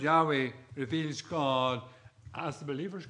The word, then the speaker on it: Yahweh: Yahweh. Again, I'll spell it for you. Y-A-H-W-E-H.